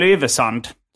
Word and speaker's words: Yvesand: Jag heter Simon Gärdenfors och mitt emot mig sitter Mikael Yvesand. – Tjena. – Är det rätt uttalat av ditt efternamn? – Ja, det tycker jag.